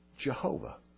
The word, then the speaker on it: Jehovah: They took the consonants, Y-H-W-H, and they inserted into them the vowels for in Adonai. And we got Yehovah. More commonly, we pronounce it Jehovah.